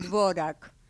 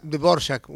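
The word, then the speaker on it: Dvořák: Por ejemplo, cuando dicen Dvorak, eso ya es un barbarismo. – Y sí, pero hay que averiguar mucho. Claro. – Es Dvorak, una cosa así.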